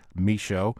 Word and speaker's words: Michot: And Michaud is spelled M-I-C-H-O-T, French pronunciation, Michaud.